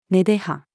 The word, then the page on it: 根出葉: female